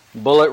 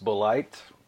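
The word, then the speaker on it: Bulleit: I did mine with the Bullet Rye, which is spelled like it should be pronounced Bullite, but it doesn't appear to be pronounced that way.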